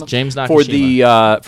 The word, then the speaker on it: Nakashima: Nakashima. – Yes, James Nakashima for the LFA Legacy Fighting welterweight title.